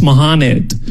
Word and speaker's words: Mohammed: So he asked me, oh, how do you pronounce this? I was like, oh, it's Mohammed. And he was like, huh. You know, actually, here in the States, it's pronounced Mohammed.